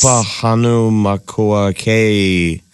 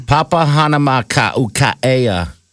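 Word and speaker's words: Papahānaumokuākea: Papahanaumokuʻea. Say that. Come on. – It is. It's Papahanaumokuʻea.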